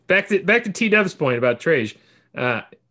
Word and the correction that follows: Tej: Tej. – T-E-J. – Tej. Like if you mispronounce Pez. Pej. Tej.